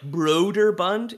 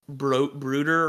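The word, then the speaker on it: Brøderbund: And some of the, like, I guess the equivalent of our French accents in some Nordic languages is like the O with the cross through it, which I believe is a long O. So it's like Broderbund instead of like Bruder or whatever, right? – I think it's called a flajorka.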